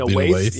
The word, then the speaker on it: Lena Waithe: Lena, Lena Waithe.